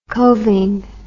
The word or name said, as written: coving